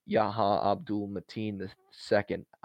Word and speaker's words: Yahya Abdul-Mateen II: From Yahya Abdul-Mateen II, I can't believe I pronounced that correctly, into Tony Todd's Daniel Robitaille.